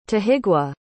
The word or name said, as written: tahigwa